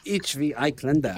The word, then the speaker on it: Dirndl: It's Ich will ein Dirndl. – Am I saying it right? – You're pretty close, actually. I think you're trying to say Ich will ein Dirndl.